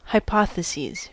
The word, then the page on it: hypotheses: American English